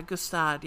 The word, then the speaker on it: Gstaad: And it talks about how she finds another husband named Kian, and they got married in, I don't know how to pronounce this, Gstaad? – Yeah, Gstaad, yeah.